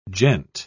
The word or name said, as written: gent